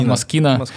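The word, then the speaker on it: Moschino: как я каждый раз неправильно произношу? Масчина, Масчина, Маскина. – Маскина, еще, ты что?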